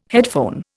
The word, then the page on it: headphone: Indian